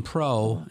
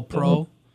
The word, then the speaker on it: Preaux: And they have a new version, the Stainless Pro, the Cajun Pro. And that, how do you spell Pro? – P-R-E-A-U-X. – Yeah, there you go. – You spell it the right way. – Good Cajun spelling.